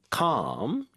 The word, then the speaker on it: calm: 그래서 anxious에 대비되는 calm 이렇게 발음해 주시면 되죠.